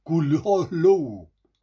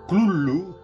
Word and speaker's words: Cthulhu: sería algo así como Cthulhu, con una primera sílaba muy gutural y viscosa. La mejor aproximación que se puede hacer es gruñir, ladrar o toser. Las muy malamente formadas sílabas, sigue diciendo Lovecraft, Cthulhu, con la punta de la lengua firmemente pegada al cielo de la boca.